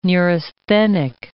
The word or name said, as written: neurasthenic